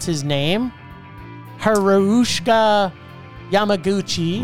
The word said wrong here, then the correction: Ryosuke Hamaguchi: Ryosuke Hamaguchi.